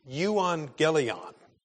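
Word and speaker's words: euangelion (εὐαγγέλιον): Now, the Greek word that is being translated here is pronounced euangelion. And it's a compound of two words, eu, that's two Greek letters there, which means well or good, and angelion, which means message.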